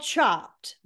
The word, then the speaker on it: Chopt: Okay, so it's called Chopped, but it's spelled Chopt.